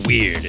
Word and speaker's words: Wyrd: It's a weird one, Joe. I'm going to warn you. It's called Weird, but it's spelled weird. It's spelled W-Y-R-D.